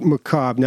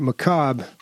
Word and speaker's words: macabre: And you wrote macabre. Now, I hate to do this, but macabre. – Doesn't it mean like... – It's spelled like that. – Oh, okay. – Yeah, silent R. – Macabre.